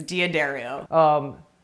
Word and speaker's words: D'Addario: Yeah, however, how do you pronounce, what is the official way to pronounce it? I've heard it so many different ways. D'Addario. – Yeah, I think that is, or we could step into a bigger mud pile and try to say piezo or piezo.